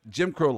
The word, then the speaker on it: Jim Crow: Jim John Brown laws. – Jim Did I just Crow. butcher that? – Jim Crow laws.